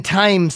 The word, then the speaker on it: thyme: I'm waiting for my shipment to come, and I want the lemon thyme sauce. Did I say that right, finally? – Yeah.